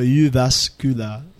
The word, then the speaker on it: Jyväskylä: Kasi yung J nila ang pronunciation ay U. Tapos yung pronunciation, yun yung mahirap kasi yung wala sa lingwahe natin. So yung J nila ay U, tapos yung U, yung U. So U. So Jyväskylä.